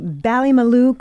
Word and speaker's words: Ballymaloe: You and your husband founded the Ballymaloe Cookery School.